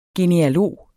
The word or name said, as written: genealog